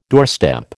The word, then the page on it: doorstep: American English